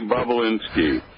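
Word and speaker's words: Bobulinski: It sounds like Bobolinsky. Is that his name? – Yes, Tony Bobolinsky.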